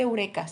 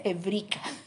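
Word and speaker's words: ebrica (εύρηκα): Que por ahí un griego me corrigió y me dijo, no se dice eureka, se dice ebrica.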